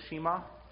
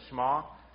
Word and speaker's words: Shema: This is called, in Hebrew, the Shema. Okay, sometimes pronounced the Shema.